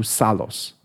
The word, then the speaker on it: Psallos: you must listen to Psallos.